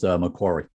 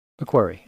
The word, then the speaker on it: Macquarie: Yeah, it's actually pronounced Macquarie. – Macquarie.